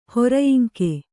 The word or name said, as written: ಹೊರಯಿಂಕೆ